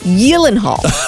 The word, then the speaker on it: Gyllenhaal: No, it's Swedish. So it should be pronounced Gyllenhaal, but we all say Gyllenhaal.